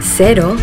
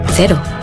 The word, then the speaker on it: Cero: In Yucatan, the local Mexican surfers pronounce cero, cero. During the warm summer evenings in bars around the coast of Nayarit, you'll hear them say cero. In New Zealand, zero alcohol Corona cero is pronounced cero. Easy, eh?